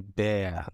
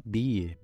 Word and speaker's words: bear: O urso em inglês é bear, né? Lembra do gummy bear? Mas muita gente fala beer. Beer é cerveja, tá? Urso é bear.